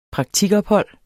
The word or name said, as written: praktikophold